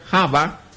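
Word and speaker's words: Java (חוה): Bueno, entonces el nombre de Eva en la etimología hebrea, Java, así se pronuncia, Java, viene a significar fuente de vida.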